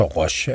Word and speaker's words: Roche: som jeg har svært ved at udtale. Roche? – Ja, men jeg tror, Roche er sådan den mest almindelige internationale måde at sige det på.